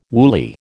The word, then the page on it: woolly: American English